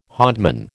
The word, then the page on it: hodman: American English